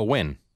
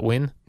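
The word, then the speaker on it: Nguyen: His name is, it's that name which I see in foreign countries sometimes that's spelled his first name, N-G. N-G-U-Y-E-N. – Oh, Win. – And his last name is, oh, that's how you pronounce that, Win?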